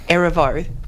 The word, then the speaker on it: Erivo: Erivo was singing live that for that thing.